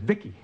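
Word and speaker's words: VICI: V-I-C-I, I pronounce it Vicky.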